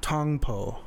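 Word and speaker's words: Tong Po: And it's pronounced Tongpo.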